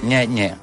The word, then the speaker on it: Gne Gne: Té una cançó que es diu Nye-Nye, que en italià s'escriu G-N-E, G-N-E. Es pronuncia Nye-Nye.